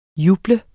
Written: juble

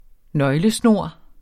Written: nøglesnor

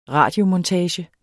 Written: radiomontage

